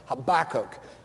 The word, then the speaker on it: Habakkuk: Just in case any of you were feeling sorry for Habakkuk, or Habakkuk, as I must now learn how to say it.